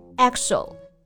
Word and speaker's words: EXO: 嗯，所以呢，你如果把字母一个一个读，确实是 e x o，但是连起来呢，这里的 e 呢就发一个 a 的音了，所以就是 exo exo exoplanet。